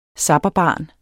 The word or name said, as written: zapperbarn